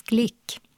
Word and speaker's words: Glück: Det blev en poet i år, amerikanska Louise Glück. Ja, det uttalas så fast det stavas Glück.